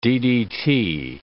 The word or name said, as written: DDT